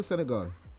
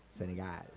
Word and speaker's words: Senegal: No, it's not Senegal. It's Senegal. – Yeah, hey, I do not pronounce Senegal. The G. I don't pronounce the G.